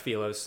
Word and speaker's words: Philos: Yeah, so it's pronounced Philos. Okay. Of course, the root just comes the same place as philosophy, love of wisdom.